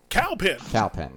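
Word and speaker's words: Kal Penn: Cal Penn, not Kai.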